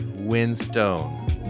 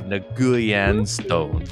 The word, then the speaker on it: Winstone: So just so you know, my name is Dave Winstone, not Dave Nguyen Stone.